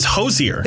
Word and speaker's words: Hozier: Is it Hozier? – It is Hozier. – H-O-S-I-E-R. – H-O-Z-I-E-R.